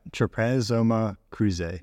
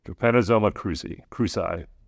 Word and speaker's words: Trypanosoma cruzi: Trypanosoma cruzi. – Yeah. Trypanosoma cruzi.